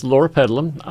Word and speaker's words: loropetalum: Purple diamond loropetalum, let me pronounce it correctly. So there's one, that's loropetalum.